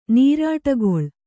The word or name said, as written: ನರುಟುಗೊಳ್ಳು